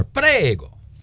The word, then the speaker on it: prego: You got to roll your R. Prego. What's the little? Come on now. – Prego.